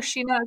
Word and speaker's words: Shin-ah: So, Evan, did you hear Sheena as well? – That sounds right.